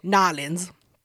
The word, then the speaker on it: New Orleans: Anyway, so Pierre recently went to New Orleans. Nolens. Let me fix that. – Blah, blah. Say it right with the Creole.